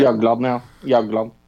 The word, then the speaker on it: Jagland: Is it Jagland? Is that how you say it? – Jagland.